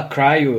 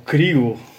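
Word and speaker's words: CRI-O: Uh, tu fala cryo eu falo crio, né? Cada um com, com a sua pronúncia.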